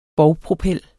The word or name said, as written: bovpropel